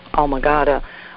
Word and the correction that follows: amygdala: Did you mean the amygdala? – Amygdala.